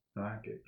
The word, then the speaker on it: Noachic: How do you think you would pronounce Noachic? – Like that. You just did. – Oh. Noachic.